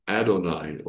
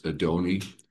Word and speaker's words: Adonai: And another word that's translated the same is Adonai, or depending on how you want to do the pronunciation, it's Adoni, Adonai.